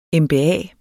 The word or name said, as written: MBA